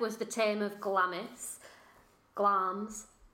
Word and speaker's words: Glamis: The Beast of Glamis Castle, which I believe is pronounced Glams. – Glams. – Glams. I don't know whether to go for just my own phonetic pronunciation or whether I have big hopes for your Scottish accent on this episode. – It's spelled G-L-A-M-I-S, the Beast of Glams Castle.